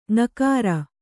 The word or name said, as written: ಙಕಾರ